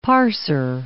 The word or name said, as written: parser